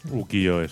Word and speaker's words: Ukiyo-e: Ukiyo-e. – Ukiyo-e, sí.